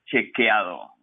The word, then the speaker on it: Chequeado: And correct me if I pronounce it incorrectly Fact Chequiado. Do I have that right? – Almost right, Bill. Uh, Is it fact chequeado, like cheque with fact. ado at the end?